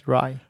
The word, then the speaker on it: RAI: And then we are doing something really special at ESPC, the European SharePoint Conference in Amsterdam at the Rai. – Yes. – Is that how you say it? – Yes, Rai.